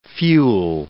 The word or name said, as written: fuel